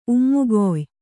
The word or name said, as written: ಉಮ್ಮುಗೊಯ್